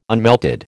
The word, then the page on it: unmelted: American English